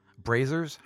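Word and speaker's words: brazier: braziers? How do you say that? – Braziers. – Yeah. Braziers. So there's large copper braziers.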